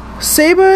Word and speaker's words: S4B3R: Saber is not spelled S-A-B-E-R. It is spelled S-4-B-3-R.